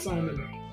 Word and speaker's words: seinen: Okay, I didn't know that was seinen. – Yeah, Simon. It's pronounced Simon. – Oh, Simon.